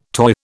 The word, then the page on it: toy: British English